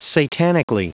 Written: satanically

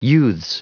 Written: youths

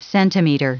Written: centimeter

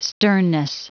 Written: sternness

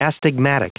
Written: astigmatic

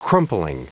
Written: crumpling

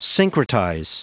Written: syncretize

